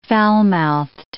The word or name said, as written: foulmouthed